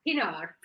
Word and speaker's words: Pienaar: Pienaar. – Am I saying it right? Quinoa? – Pienaar.